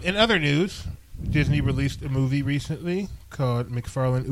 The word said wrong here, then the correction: McFarland: USA. – McFarland, USA. – Oh, they renamed it? – Yeah. – I'm pronouncing that wrong? – Yes.